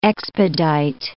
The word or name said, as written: expedite